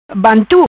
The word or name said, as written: Bantu